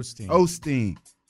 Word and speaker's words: Osteen: How do you say his name? – Osteen. – Joel Osteen. – Yes.